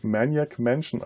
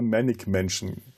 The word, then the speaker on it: Maniac Mansion: Also im Vorgängerspiel äh, Maniac, Man Ma Maniac, äh, ich kann es bis heute nicht Maniac Mansion aussprechen, weil es bei uns aus irgendeinem Grund, wir haben immer Manic Mansion dazu ja, gesagt.